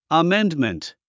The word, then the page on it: amendment: əméndmənt; アメンドメント